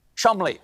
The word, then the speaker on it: Cholmondeley: The Marchioness of Cholmondeley. What a beautiful name that I'm being told right now that I pronounce incorrectly. It is, in fact, pronounced Chumley.